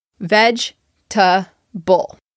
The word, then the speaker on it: vegetable: To me, vegetable has three syllables. Veg-ta-ble. The second E in the word is silent.